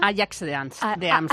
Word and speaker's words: Ajax: Ajax de Ámsterdam.